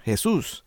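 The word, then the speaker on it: Jesús: It's pronounced Jesus, and Jesusita is a girl's name for Jesus.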